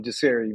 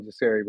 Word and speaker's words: Jasiri: I'm Jasiri, man. You was gonna have to. I'd rather you mispronounce it, but you're gonna call me Jasiri.